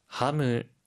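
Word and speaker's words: ハム: 하무, 하무, 햄인데요. 혹시 발음 팁 있을까요? – 하이, 소세지는 시가 아니라 세로 발음을 해야 하고요. 함으라고 할 때 끝의 소리를 무로 발음합니다.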